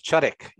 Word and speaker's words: Chudyk: You're pretty close. Chudik, yes.